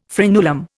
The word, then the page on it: frenulum: Indian